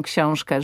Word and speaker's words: książkę: ale że powie książkę, że to ę zabrzmi nie jako książkę, bo to jest błąd. Tak, tak. To jest hiperpoprawna wymowa, książkę, rękę, ale że to ę wybrzmi, rękę.